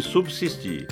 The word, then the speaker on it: subsistir: A pronúncia correta é subsídio, utensílio e subsistir. Todas com S.